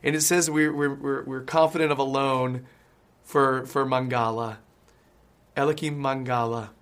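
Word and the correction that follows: Eliaquim: Eliquim, I think it is. Something like that. – Eliquim. – That's the one.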